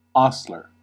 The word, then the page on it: hostler: American English